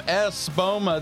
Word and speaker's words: Espoma: I'm going to say that correctly this time. Espoma.